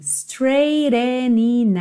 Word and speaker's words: straightening: E essa frase final, olha, we've got some straightening out to do, straightening out to do. Porque, ó, straightening, o GH é mudo, então você tem straight, o GH é mudo.